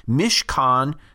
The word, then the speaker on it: mishkan: If you were to spell that out, transliterated, it's M-I-S-H-K-A-N, and it's pronounced mishkan.